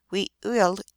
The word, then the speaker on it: Wiwilmekq: The correct pronunciation is very early Wiwilmekqu for both Penobscot and Passamaquoddy, but this would be a difficult utterance for anyone who has never listened to the Algonquin soft gutturals.